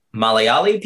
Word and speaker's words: Malayali: And it's funny because like Indian people will ask me if I'm Indian, particularly Malayali people. Mm-hmm. Am I pronouncing that correctly? – Yeah, they'll ask.